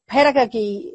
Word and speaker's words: pedagogy: So it's your pedagogy, how do you say it? – Pedagogy. – Pedagogy. Yeah. Thank you.